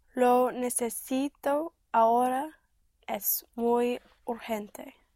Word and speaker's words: urgente: And in Spanish, the G before E and I is pronounced like in Scotland, the Loch Ness. Loch. Like it's. So urgent would be urgente. Urgente. But spelt with a G.